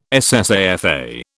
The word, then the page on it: SSAFA: British English